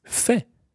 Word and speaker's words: fait: So the past participle of faire is fait. – Fait. – That's spelled F-A-I-T.